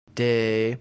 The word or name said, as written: de